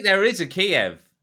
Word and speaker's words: Kyiv: So, a mysterious flash of light that lit up the skies over Kyiv, or Kiev. I don't know how you pronounce it anymore. So many people are talking. – Kyiv, yeah, we'll go for Kyiv.